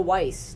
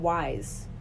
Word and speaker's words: Weis: It's actually pronounced, hang on. What is it actually pronounced? Because I just call it Weiss. It's called Wise. – Weiss, like W-I-S-E, okay. But it's W-E-I-S, so I call it Weiss.